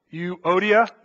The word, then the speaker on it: Euodia: I've been trying to say this name, and I've been butchering it every time. Eudoia, someone help me out here. You know, I even read this out. I had the computer read it out loud to me so I wouldn't butcher it. And I was sitting at home, practice saying, Euodia. There it is. I got it.